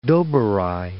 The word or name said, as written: Doberai